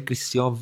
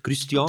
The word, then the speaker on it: Christian: Je m'appelle Christian Wirt. Christian? Das ist wahrscheinlich falsch ausgesprochen.